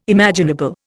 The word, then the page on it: imaginable: Indian